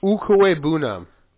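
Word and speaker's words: Okwuegbunam: Ukwebunam. – That is not how I have heard Al Michaels say his name. I believe you. Don't get me wrong. Okwebunam.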